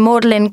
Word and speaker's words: Magdalene: For example, L-E-I-C-E-S-T-E-R is pronounced Leicester. G-R-E-E-N-W-I-C-H is Greenwich. And M-A-G-D-A-L-E-N-E, as in Magdalen College, Cambridge, is Magdalen.